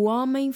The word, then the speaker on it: homem: O H é silencioso. Não pronunciamos o H no início das palavras. Nunca. O homem. E o M no fim, não é homem. É homem. O Vem homem. Do nariz, não é? – Hum.